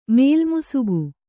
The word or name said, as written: ಮೇಲ್ಮುಸುಕು